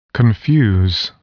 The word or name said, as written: confuse